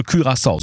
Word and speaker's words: Curaçao: Nee, Curaçao, weil es glaube ich niederländisch ist, ne? – Ja, richtig, ja, das okay. ist richtig. – Also Curaçao, so spricht man es aus.